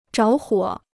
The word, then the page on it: 着火: zháo huǒ